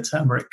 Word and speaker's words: turmeric: Naively, we used to think it didn't matter, but it is officially turmeric. So we are officially the Turmeric Co. And we heavily push turmeric now as the correct pronunciation.